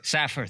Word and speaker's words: Safar: It's John Moon, Dr. Nancy Caroline, and Dr. Peter Safar, or Safer, maybe me pronouncing it wrong. Let's tie them together. – Safar, thank you very much.